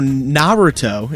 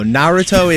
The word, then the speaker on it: Naruto: So Naruto is how it's sorry. – Actually pronounced. – Forgive my ignorance. – How he said it. – Forgive my ignorance on the subject. – Well, let me just said it wrong. – He also said it wrong. He said Naruto. – Via a Naruto run. – Yeah, it's Naruto.